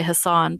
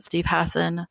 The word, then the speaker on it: Hassan: And I distinctly remember Steve Hassan, sometimes people say Hassan, but he pronounced it Steve Hassan, on the show, and he had a book, and I had this feeling that was like, I need to read that book.